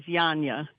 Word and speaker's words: Janja: No, the first name is Janja.